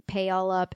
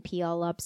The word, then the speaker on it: Puyallup: But Pialup, and I've heard it pronounced Pialup and Pialup, so I'm sorry if you're from there and I'm really butchering that. But just so you know, in documentaries, in audiobooks, it is said very differently.